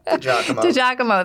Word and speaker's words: DiGiacomo: DiGiacomo, thank you. Yeah. I had it in my head. I was pronouncing it differently, but then you're like, no, it's not how it's pronounced.